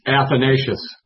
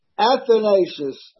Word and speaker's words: Athanasius: Athanasius. – Athanasius.